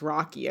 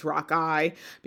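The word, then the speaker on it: rockii: So, they're also known as uh, I don't think it's pronounced Rocky. I think it's Rock Eye because uh, there's there's a man whose name is Joseph Rock who helped them make make them famous outside of China.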